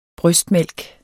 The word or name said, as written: brystmælk